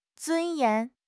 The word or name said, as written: 尊严